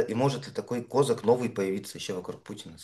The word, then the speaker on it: Козак: Сейчас как будто бы надежды совершенно нет, но вышло вот расследование, несколько расследований про Дмитрия Козака, благодаря которым мы понимаем, что и вокруг Путина могут возникнуть люди, которые попытаются схватить его за руку. Вкратце людям напомню, что Дмитрий Козак, некоторые ставят ударение как казак,